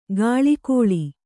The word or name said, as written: ಗಾಳಿಕೋಳಿ